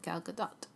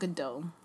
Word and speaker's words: Gal Gadot: She said, Gal Gadot. – Okay. – It's not Godot.